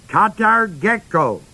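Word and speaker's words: Katargeko (καταργέω): The Greek word for loosed is spelled K-A-T-A-R-G-E long O. Katargeko, K-A-T-A-R-G-E long O.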